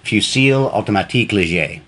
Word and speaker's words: Fusil Automatique Léger: The Fusil Automatique Léger, I believe, is the pronunciation of...